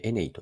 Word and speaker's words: ENEY: で、エネイは英語で ENEY と書いてエネイと読むんですが、これは 2 つの単語から組み合わせた造語で、エニーとエナジーなんです。